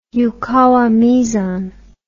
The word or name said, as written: yukawa meson